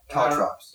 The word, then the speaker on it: caltrops: Caltrips. I think they're called... – Caltrops. – Caltrops, yeah. Caltrops. There's, like, little, like, spiky...